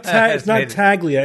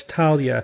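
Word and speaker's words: tagliatelle: It's not Taglia. It's Talia. – Talia. – Yeah. GL is like LY. Tagliatella.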